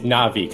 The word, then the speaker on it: Na'vi: It's Na'vi because there is an accent between the syllables, so Na'vi.